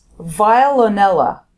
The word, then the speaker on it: Veillonella: It's Veillonella. Vile or Veillonella, Veillonella. – Like Kurt Veillonella. – Like Kurt Veil.